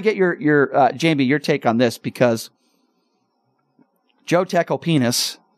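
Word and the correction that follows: Tacopina: sorry, I mispronounced his name again. Donald Trump's attorney, Joe Tecopena, talked about that Access Hollywood tape, you know, grab him by the P word.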